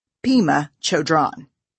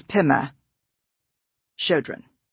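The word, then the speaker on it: Pema Chödrön: When you quoted Pema Chodron, you said Pema Chodron. The correct pronunciation is Pema Chodron.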